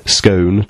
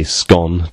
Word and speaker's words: scone: A gentleman named Toot, I don't know if that's his real name, said, some say scone to rhyme with own, Most say scone to rhyme with gone.